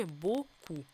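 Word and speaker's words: beaucoup: Э, а, ю мы читаем как О, о, ОЮ мы читаем как У. Помним, что это закрытый О, поэтому глубокий звук. У тоже, чтобы не улетело ни в какое Ю. А, П на конце не произносим, и буква С перед О тоже читается у нас как э, К, а не С. Ну и получили боку.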